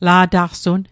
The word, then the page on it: la'taqsun: Mi'gmaq